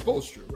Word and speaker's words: Spoelstra: Do you think Eric Spoelstra, I want to pronounce the S, but it's Spoelstra, right? – Yeah.